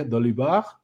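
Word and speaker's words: Dolibarr: francés, por lo tanto se pronuncia Dolibar.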